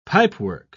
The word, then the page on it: pipework: British English